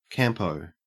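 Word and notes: campo: au Australian English

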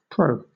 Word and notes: pro: British English

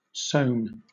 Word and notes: sewn: British English

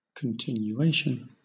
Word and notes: continuation: British English

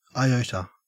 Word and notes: iota: Australian English

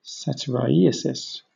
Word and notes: satyriasis: British English

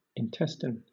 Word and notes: intestine: British English

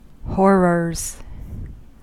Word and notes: horrors: American English

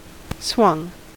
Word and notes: swung: American English

